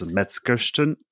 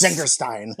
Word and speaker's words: Metzengerstein: Jane Fonda led, I don't even know how to say the title of this, Metzengerstein. – Metzengerstein. – Thank you. – Yeah. Yes. Metzengerstein.